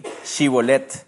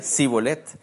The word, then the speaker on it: Shibolet: Entonces le decían, ahora pues, di Shibolet. Y él decía, Sibolet, porque no podían pronunciarlo correctamente.